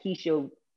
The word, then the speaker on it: Keshia: Keshia Williams, or my author page is uh, Keshia L.